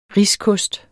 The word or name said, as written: riskost